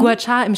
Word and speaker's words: Gua Sha: Also wird Gua Sha ausgesprochen? – Gua Sha im Chinesischen, ja.